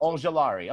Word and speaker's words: Ojulari: I think it's Ojulari, but whatever, however you want to pronounce it. – Ojulari. Okay. All right, good. I thought the L was silent.